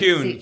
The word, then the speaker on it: Cuan: Cuan.